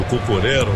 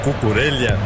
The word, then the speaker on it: Cucurella: Everton falava do Cucurella, o Cucurella, como queiram, Cucurella, são várias as pronúncias aceitáveis, né?